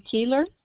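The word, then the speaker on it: Keillor: Well, I guess if you can take down my name, it's Veda Keeler.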